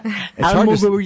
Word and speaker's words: Alamogordo: Alamogordo. Can you say it like that? – Alamogordo, you gram it all together.